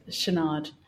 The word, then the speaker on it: Chouinard: Yes, Chouinard.